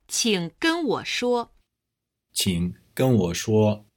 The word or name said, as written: qing gen wo shuo